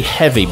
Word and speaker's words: HRVY: That is by, I think it's supposed to be heavy, but it's HRVY and Malou Traveco, I believe.